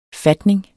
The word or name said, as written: fatning